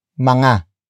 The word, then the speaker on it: mga: But before that we have mga. Mga. Mga. Mga is spelled as M-G-A.